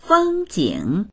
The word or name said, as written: feng1jing3